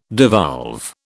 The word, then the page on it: devolve: British English